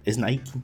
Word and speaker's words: Nike: ¿Nike o Nike? Ya, yo digo Nike ya. – Yo Nike, pero creo que es Nike, ¿no? – Es Nike.